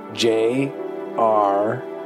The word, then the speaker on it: Jraxio: But if you need to call me something, call me Jraxio. – Jraxio? How do you... Okay, how do you spell that? – J... R-A-X-I-O.